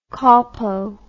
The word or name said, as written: colpo